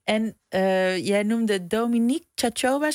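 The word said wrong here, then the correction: Luminița Cioabă: zeg ik dat goed? – Lumanica Choba, wie is dat? – Dat is een Romanese schrijfster uit Roemenië.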